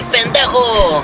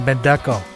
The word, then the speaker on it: pendejo: I always get confused on how to pronounce that because the first time I heard it was in Cheech and Chong, and he kept saying Bendeco.